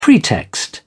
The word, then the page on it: pretext: British English